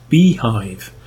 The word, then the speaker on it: bhyve: Bhyve, which is pronounced Beehive, the BSD hypervisor, which is quite neat, actually.